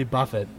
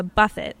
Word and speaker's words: Buffett: Well, I'm always down to play some Jimmy Buffett, forgive me. – It's pronounced buffet, I think.